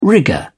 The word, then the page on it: rigour: British English